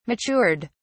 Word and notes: matured: British English

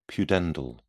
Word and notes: pudendal: British English

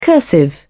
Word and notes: cursive: British English

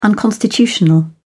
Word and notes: unconstitutional: British English

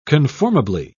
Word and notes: conformably: British English

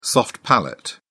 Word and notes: soft palate: British English

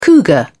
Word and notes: cougar: British English

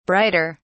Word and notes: brighter: British English

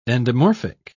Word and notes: endomorphic: British English

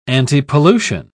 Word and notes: antipollution: British English

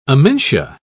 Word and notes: amentia: British English

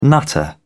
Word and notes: nutter: British English

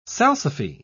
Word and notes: salsify: British English

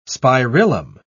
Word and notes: spirillum: British English

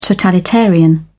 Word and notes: totalitarian: British English

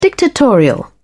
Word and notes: dictatorial: British English

